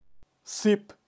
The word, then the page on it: sip: Kaqchikel